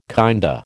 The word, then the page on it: kinda: American English